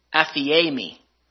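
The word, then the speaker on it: aphiemi (ἀφίημι): That the Greek word left is the word apathemia. I'm sorry, aphiemi. I said that wrong.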